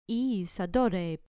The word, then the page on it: isa dore: grec